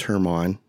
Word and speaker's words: German: Everyone's always like, you know your name's Herman. Like, oh yeah, that's my name. That's my name. Okay. It's spelled G-E-R-M-A-N.